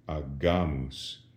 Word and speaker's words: agamus: And this does something to the way we pronounce the G. When there was an I following the G in ecclesiastical Latin, that softened the G. We said agimus. But with an A, the G goes back to being a hard G, agamus.